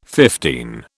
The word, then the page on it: fifteen: British English